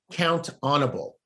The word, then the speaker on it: Count-On-Able: And the way it's pronounced is count-onable.